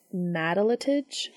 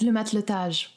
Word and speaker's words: matelotage: The concept is, it's a French word for seamanship. And I looked up the pronunciation and they said it's matelotage. – Yes, le matelotage. Yeah.